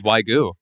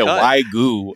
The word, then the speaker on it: Wagyu: It was Wagyu. – Yeah. – Butchered that. You said Wagyu. It's Wagyu.